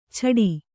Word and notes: ಛಾಟಿ: Kannada

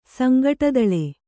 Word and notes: ಸಂಗಟದಳೆ: sangaṭadaḷe

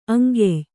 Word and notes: ಅಂಕ್ಯ: aŋkya; kannada

